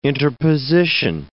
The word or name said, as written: interposition